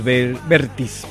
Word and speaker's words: Vértiz: Así es, el gran Pedro Suárez Vértiz. Perdón, lo pronuncié mal. Vértiz.